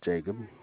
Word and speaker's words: Jacob: Jacob. – Spell it. – J-A-C-O-B.